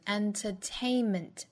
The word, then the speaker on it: entertainment: 我们注意娱乐这个词 entertainment 的发音。第一个元音呢发 a 后面跟一个鼻音， enter 后面第三个音节重读 entertainment 整体